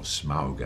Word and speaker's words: Smaug: I mean, the master of Lake Town, this rather smelly city that's on stilts in the lake below the mountain where Smaug the dragon lives. Or Smaug, actually, as you're supposed to pronounce him.